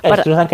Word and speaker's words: Struzan: Bueno, pues Drew Struzan o como cada uno lo quiera pronunciar, nosotros decimos Struzan Estruzan, que es más fácil.